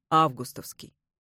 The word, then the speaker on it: августовский: Там нам советуют говорить с ударением на первом слоге «августовский».